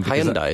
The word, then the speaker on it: Hyundai: Hyundai, isn't it? – Yeah, well, yeah, Hyundai Motors.